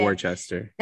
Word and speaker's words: Worcester: Yeah, Worcester.